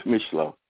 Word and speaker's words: Micheaux: It's spelled M-I-C-H-E-A-U-X. – You know, I thought it'd be like Misha or something like that. – Yeah, well, it's Michelot.